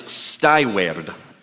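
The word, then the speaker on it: stīweard: It's always fun to pronounce things in Old English, but it's you got to kind of roll the R's. It's stiward.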